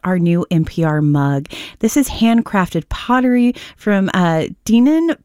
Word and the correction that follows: Dinan: Pottery. Am I saying that right, Zach? – It's Dineen, I believe.